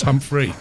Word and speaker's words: Humphrey: Because there are no humps there. It's Humphrey. Go on.